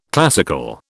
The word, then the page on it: classical: British English